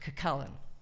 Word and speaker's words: Cuchulainn: The first part, I called it Cuchulainn versus Kushner. Cuchulainn being the Irish national hero, but then an Irish friend of mine told me that's not how it's pronounced. It's pronounced Cuchulainn.